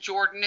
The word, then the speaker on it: Jordan: As for who I am, friends out there listening, it's M-I-C-H-A-E-L-E. And then Jordan is spelled as you would expect, J-O-R-D-A-N.